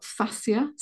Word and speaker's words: fascia: So he also talked about fascia. Now, fascinating fascia, or fascia, some people pronounce it, is such a complex connective tissue structure in the body.